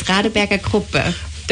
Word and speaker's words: Radeberger Gruppe: It's Radeberger Gruppe.